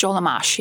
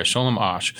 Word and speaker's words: Sholem Asch: And how do you say that playwright's name? Is it Sholem Ash? – Sholem Ash, yeah. – Yeah, okay, Sholem Ash.